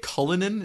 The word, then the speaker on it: Cullinan: How do we say it? Cullinan? Is that how it's said? – Yeah, I believe so.